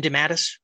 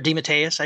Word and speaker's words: DeMatteis: Well, some of my favorites from comics, J.M. DeMatteis, or DeMatteis, I should say, I mispronounced his name.